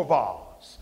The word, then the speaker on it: vase: I told you that the dictionary says you could pronounce it vase or vase. Now, how do you know the difference? If you buy it at Target, it's a vase.